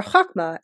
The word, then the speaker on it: Chokhmah: As the energy moves from the crown, it moves down into Chokhmah, or Chokhmah, if you want the proper Hebrew pronunciation, which stands for wisdom.